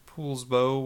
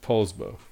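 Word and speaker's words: Poulsbo: Valerie from Poolsbo, Washington. Hopefully I said that right. – No, you didn't. Poolsbo.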